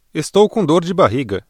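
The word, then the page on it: barriga: Brazilian Portuguese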